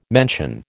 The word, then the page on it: mention: American English